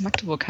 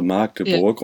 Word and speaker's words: Magdeburg: Genauso wie du es auch gut gesagt hast, dass es Magdeburg heißt und nicht ja. Magdeburg. – Ich habe gerade jemanden am Wochenende in der U-Bahn gesehen, der es genauso falsch ausgesprochen hat, also Magdeburg.